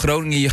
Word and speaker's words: Groningen: What was this city pronounced? – Yeah, I still can't. I'm trying. – Groningen. – Groningen. – Groningen. – Groningen. Groningen. Groningen. Yes.